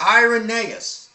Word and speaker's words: Irenaeus: It's pronounced Irenaeus.